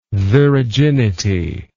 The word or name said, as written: viraginity